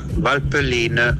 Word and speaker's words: Valpelline: La suppa alla vapelenense, direttamente da Valpelline. – E devi dire tutto con la bocca un po' strettina. Valpelline.